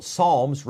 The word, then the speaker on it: psalm: But a psalm, and it's spelled P-S-A-L-M. Of course, the P is silent.